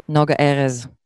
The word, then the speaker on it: Noga Erez: Noga Erez.